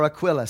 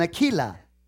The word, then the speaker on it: Aquila: Priscilla and Aquila, or Aquila, some people will pronounce it. It's Priscilla and Aquila.